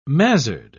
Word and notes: mazzard: British English

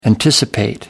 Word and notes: anticipate: American English

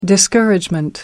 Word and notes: discouragement: American English

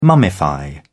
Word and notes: mummify: British English

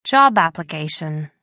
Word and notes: job application: American English

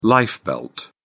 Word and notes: lifebelt: British English

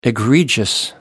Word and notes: egregious: American English

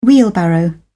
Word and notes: wheelbarrow: British English

